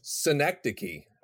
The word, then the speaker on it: synecdoche: Synecdoche.